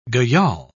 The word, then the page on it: gayal: British English